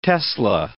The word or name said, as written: tesla